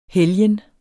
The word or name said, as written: helgen